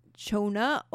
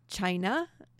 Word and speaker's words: China: Chona bark, in the natural, created symptoms of malaria, while made into the homeopathic form, which is what we call chona or china. So a lot of people refer to this medicine as china nowadays. It looks like china when it's spelled, so why not call it china?